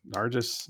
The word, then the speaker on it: Nargis: So May 2nd, 2008, Cyclone Nargis, I think I'm saying that right. Nargis. Nargis. I'll, I'll allow it.